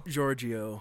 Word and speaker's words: Giorgio: I think it's Giorgio. – Giorgio, yeah. – Gregorio? – No, it's not Gregorio. – Gregorio? – Giorgio.